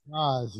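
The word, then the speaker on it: Gose: I'm not sure how to pronounce his last name. It's G-O-S-E. – Yeah, Gaz, yes, sir.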